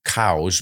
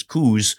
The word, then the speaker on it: Coues: And he pronounced his name Cowes, but everybody looks at the spelling C-O-U-E-S and says Coos.